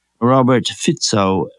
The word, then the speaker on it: Robert Fico: The election of the new Slovakian Prime Minister, Robert Fico, I understand is the way to pronounce it.